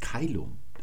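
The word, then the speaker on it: caelum: Die Römer hatten dagegen ein anderes Wort und das ist Keilum. Das wurde als C-A-E geschrieben in der klassischen Zeit und das C, das spricht man immer als K aus und A-E spricht man im klassischen Latein als Ei aus.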